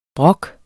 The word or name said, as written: brok